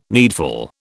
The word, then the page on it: needful: British English